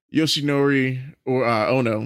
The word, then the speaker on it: Yoshinori Ono: We have Yoshinori Ono.